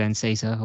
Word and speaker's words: Enciso: Is it Anisco? – I thought it was Enciso or whatever. I don't know. – Enciso, I can't remember how it's pronounced. – Enciso, I mean, look, we're looking at you for name pronunciations. You were calling Saka Sacco for so long. – And Enciso, Julio Enciso.